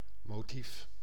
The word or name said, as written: motive